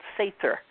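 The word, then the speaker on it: seiðr: I wanted to ask you about this word, S-E-I-O-R, and how do you pronounce that? – It's a funny-looking D. It's called Sather.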